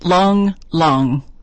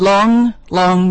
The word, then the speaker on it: Lang Lang: The pianist Long Long said that's how he pronounces it, although it looks to us maybe like Lang Lang, but he said Long Long means great man.